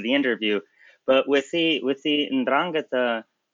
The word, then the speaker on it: 'Ndrangheta: So the 'Ndrangheta, and you pronounce it very well, congrats.